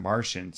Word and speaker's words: Marcion: No, it, I but I, would, I always M-A- thought it was Marcion, M-A-R-C-I-O-N. Yeah, but they they pronounce it Martian, so I don't know.